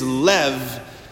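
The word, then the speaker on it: lev (לב): And biblically speaking, when we're talking about the heart, the Hebrew word for heart is lev, spelled L-E-B, lev. B is pronounced like a V, just to confuse you.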